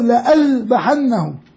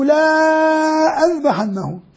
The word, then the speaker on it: لأذبحنه: الألف هذه لا ينطق بها، لكن كان تكتب زمن الصحابة ويسمى حرف ساكن يكتب ولا ينطق. فبتقراه أنت أو لأذبحنه، لو بدك تقراه زي ما مكتوب تقول أو لا أذبحنه، تطلع مش راح يذبحه، هو صمم يذبحه إذا ما أتى بهتان.